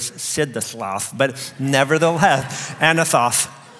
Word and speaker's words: Anathoth: Everyone say Anathoth. And it sounds at the end like you're lisping, Anathoth, but that's correctly.